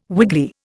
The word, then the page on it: wiggly: Indian